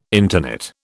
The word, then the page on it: interknit: British English